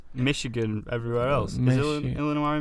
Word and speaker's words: Illinois: And on the 24th, a small group of people gathered outside Dorothy's house, which is in Illinois, that's how you say it.